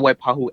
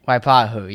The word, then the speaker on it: Waipahu: Oh, it's the Waipahu FFA chapter. – Waipahu, yeah.